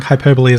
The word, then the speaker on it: hyperbole: How for years people think hyperbole is hyperbole. – Hyperbole, yeah. And if you say hyperbolic, you do say hyperbole.